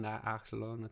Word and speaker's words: naloxone: Is it naloxone? Naloxone.